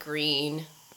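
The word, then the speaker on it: Gruene: And if you haven't ever been around and down and around in Texas, we have these really cool little German towns like Green and Fredericksburg. No, Green is not spelled like G R E E N. No, it's, G R U E N E.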